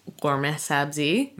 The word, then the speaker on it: ghormeh sabzi: People will say gourmet sabzi. It's spelled G-H-O-R-M-E-H S-A-B-Z-I.